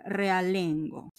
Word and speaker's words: realengo: Andaba soledad con un perro ralengo. Con un perro ralengo. Perro ralengo que es un un perro que no tiene pata. Así no se dice. Vamos a investigar. Realengo.